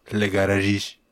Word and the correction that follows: Illusione Garagiste: No, I think it's Illusione Garagiste.